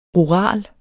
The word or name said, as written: RURALE